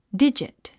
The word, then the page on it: digit: dídʒit; ディジット